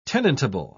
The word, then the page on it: tenantable: British English